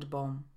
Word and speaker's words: bomb: Panie, które używają rozświetlaczy Riany, pewnie znają diamond bomb. Często słyszę wymowę diamond, bomb, a powinno być diamond ze szwa i niemym b, bomb.